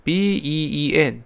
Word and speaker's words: been: 但是我们昨天讲的那个 been b e e n 这个不一样，这个在美音中呢，那个 e e 发 e 的声音，口型大一些，所以读成。 been been been，但这个看看它的呃过去分词都是 seen，注意这不一样哦。